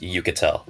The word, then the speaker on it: Uchitel: If it helps, I think the first name is pronounced Uchitel, Rachel Uchitel.